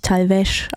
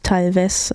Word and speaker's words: Talvez: Natürlich Talvez ausgesprochen, nicht Talvez, wie ich es eben anmoderiert habe.